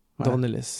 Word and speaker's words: Dornelles: de Kleber Mendoza Filho et Juliano Dones. – Dornelles. J'y suis arrivé.